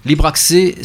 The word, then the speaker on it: Libracces: Libre access mais que l'on prononce libre accès.